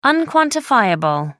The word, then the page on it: unquantifiable: American English